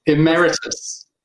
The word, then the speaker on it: Emeritus: And I'm going to keep mispronouncing his title. It's really annoying because we rehearsed it before. Emeritus Professor Robert West.